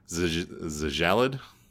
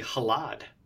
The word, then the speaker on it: Xjallad: So how would you pronounce X-J-A-L-L-A-D? Zjallad? – Either Zjallad, or I might say, because both X and J can sometimes be H's phonetically, it might just be Halad.